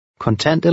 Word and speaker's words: Kontant: The stress in the first word is on the second syllable. – Kontant eller kort? – 11.